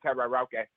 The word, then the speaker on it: karaoke: Are we even saying that right? Karaoke. – It's karaoke, but that's okay.